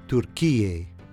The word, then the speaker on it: Türkiye: With the two dots above the U, it's pronounced U, similar to the sound of U in the English word pure. Türkiye.